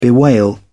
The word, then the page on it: bewail: British English